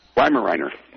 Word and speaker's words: Weimaraner: How long did it take you to train the Weimaraner? Did I say that right? – Weimaraner. – Weimaraner.